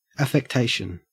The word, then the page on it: affectation: Australian English